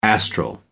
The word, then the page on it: astral: American English